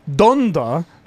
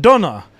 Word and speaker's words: Donda: And he writes, in correcting conversations, the album is called Donda, not Donna. Please say it right when you're calling out the Patreon list. It's been driving me crazy hearing you say it that way, like, every week.